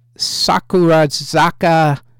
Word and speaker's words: Sakurazaka: Top Japanese song is Sakurazaka 46. There we go. Got the emphasis on the right syllable.